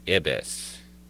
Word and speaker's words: ibis: It's pronounced ibis, like you just said, she calls out to him, nearly making him jump right out of his seat.